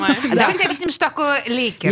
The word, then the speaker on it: Леккер: Все ли я правильно произношу? – Лейкер, правильно Лейкер.